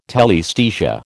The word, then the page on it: telaesthesia: American English